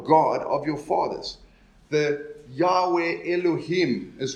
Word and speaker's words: Yahweh: And you know, we know we get those, the word, the tetragrammaton, really, the YHWH that we pronounce as Yahweh. You know, some people pronounce it as Jehovah.